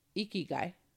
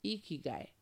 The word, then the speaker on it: ikigai: Ikigai. It's hard to say because I'm not Japanese, but I love it. It's spelled I-K-I-G-A-I, ikigai, okay?